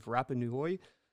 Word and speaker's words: Rapa Nui: But more specifically, his research is focused on ancient interactions of the people on the island of Rapa Nui.